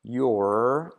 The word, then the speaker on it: your: Shen, letra clave SH, live, live, LIFE ah, live es your, your, letra clave R, your, y esa R casi no se dice en la canción.